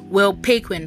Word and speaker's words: Will Paquin: When writing this poem, I listened to Monster and Chain Smoking by Jacob Banks and Chandelier by Will Paquin. I think that's the correct pronunciation. Will Paquin, that is spelled P-A-Q-U-I-N.